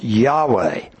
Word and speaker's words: Yahweh: It was, in all capital letters, IHWH, which is pronounced Yahweh.